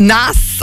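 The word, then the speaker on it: Naas: Can't you tell I'm from Nice? – It's Nass, actually. – I'm from Nass, actually. – It's pronounced Nass.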